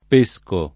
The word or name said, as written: pescare